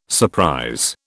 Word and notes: surprise: British English

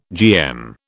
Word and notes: GM: British English

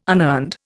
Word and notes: unearned: Indian

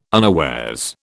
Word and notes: unawares: British English

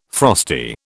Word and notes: frosty: British English

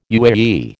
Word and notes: UAE: American English

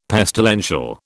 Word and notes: pestilential: British English

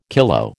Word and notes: kilo: American English

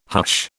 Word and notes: hush: British English

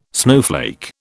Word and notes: snowflake: British English

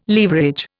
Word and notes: leverage: Indian

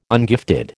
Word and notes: ungifted: American English

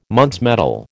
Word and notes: Muntz metal: American English

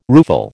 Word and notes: rueful: American English

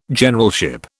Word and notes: Generalship: British English

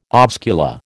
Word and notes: oscula: American English

